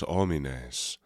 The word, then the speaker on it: homines: And notice that you don't need to pronounce the H on those kind of silent H's, homo and what was the other one? Homines.